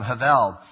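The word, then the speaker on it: Havel (הבל): It's spelled with a B, but it's with a V sound, Havel.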